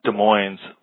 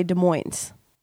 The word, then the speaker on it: Des Moines: Okay, well, that's what I want to address, is that in Washington, we have a Des Moines, but you say Des Moines. Des Moines, Washington. You pronounce the S. – Oh, really? – You do. – Oh. – Here, people... – Des Moines? Yeah. Do I? – But the Des Moines, Iowa, you do not.